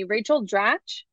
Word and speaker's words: Rachel Dratch: Rachel Dratch.